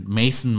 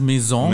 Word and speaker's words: Maison: Und teilweise auch falsch aussprechen, wie Mason Margiela oder so. Ich meine, ey, no hate, man, aber das ist falsch. Du musst es Maison mal sagen.